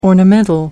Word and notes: ornamental: American English

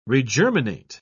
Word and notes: regerminate: British English

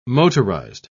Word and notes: motorised: British English

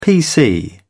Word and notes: pc: British English

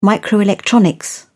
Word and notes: microelectronics: British English

